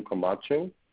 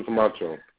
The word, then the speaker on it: Camacho: My name is Angel Camacho. – David. You yes. – are with David. Okay, let me connect you with that. Can I have your name, please? – Angel Camacho. – Miss Malikum. – Angel is spelled A-N-G-E-L. Camacho is spelled C-A-M-A-C-H-O.